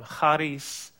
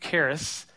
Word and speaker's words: charis: And so this word charis, or properly pronounced, which I'm not going to do but one time, charis, that's the Greek word for grace. We're going to Americanize it and make it charis.